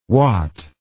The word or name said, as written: wat